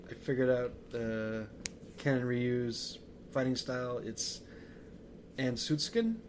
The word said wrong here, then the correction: Ansatsuken: Ansatsuken. Yeah, it's hard to pronounce. Okay. – Yes. There you go. – You said it was a mouthful. – Yeah. – I guess it is. – It's and like, more phonetically, it's Ansatsuken.